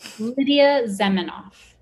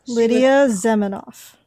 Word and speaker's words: Lidia Zamenhof: Lidia Zamenhof. – Okay. – Lidia Zamenhof.